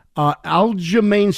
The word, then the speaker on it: Aljamain: And he apologized to this guy, Aljamain Sterling. Did I get that right? – Nailed that one.